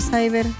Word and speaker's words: Cyber: ¿Cómo se dice ciber o cyber? – Cyber. – Es que mucha gente dice ciber. – También le pueden decir ciber.